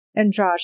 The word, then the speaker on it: Andrade: And I would like to see Jessica, is it Andrade? – Andrade, that's it. Sorry, bad pronunciation.